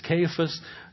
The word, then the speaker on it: Cephas: Sometimes he's called Cephas or Cephas. You've heard it pronounced. In the Aramaic, it is Cephas.